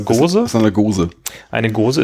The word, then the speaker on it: Gose: Ist das, spricht man das auf Englisch auch Gose? Ich hätte jetzt Goose gesagt, ehrlich gesagt. Aber Goose ist ja eigentlich ganz, das wird, glaube ich, mit Doppel-O geschrieben, oder?